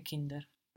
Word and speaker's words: Kind: But it's spelled K-I-N-D. So again, like in bald, soon, like in Mund, mouth, rund, round, grund, ground, we have the D on the end of the syllable pronounced like a T.